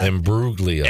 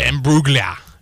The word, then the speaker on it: Imbruglia: Imbruglia. – Imbruglia.